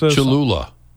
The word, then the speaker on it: Cholula: Cholula.